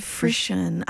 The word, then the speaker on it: frisson: Frisson. I think it's okay, like frisson is like the French, maybe the etymology of it.